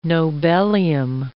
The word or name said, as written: nobelium